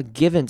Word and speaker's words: given: 好，那我们来看到 given 这个字也是要注意的是它的短母音的声音，这边 I 要念成短母音 I，所以要念成 given。好，那后面的 V-E-N 呢会稍微就用一个嗯的声音带过 given。